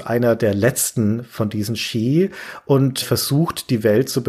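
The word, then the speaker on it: Sidhe: Ski spricht man das aus. – Ski spricht man das aus? – Ja, das ist ja ein Originalname aus der keltischen Mythologie.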